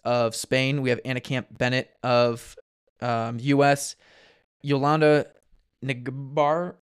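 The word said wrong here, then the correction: Ngarambe: No, how do you say that, Eric? You're good with this. Garambe? – Garambe, yes. Garambe, correct.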